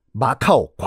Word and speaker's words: 마카오: 마조 신을 모시는 사당이 이 사당 이름이 뭔지 물어보는 건 줄 알고 여기요 마각이요 마각의 마카오 광동식 발음이 마카오 거든요. 그래서 얼떨결에 그 동네 이름이 마카오가 된 거예요. 마카오는 중국 발음이 아니에요.